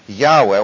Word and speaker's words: Yahweh: And again, let's try to establish the habit of not using Jehovah, not using Lord, but using the correct pronunciation for God's name, the one single name that is His in the Old Testament, which is should be pronounced Yahweh, Y-A-H-W-E-H, and it's the idea of He is the self-contained or self-sustaining God.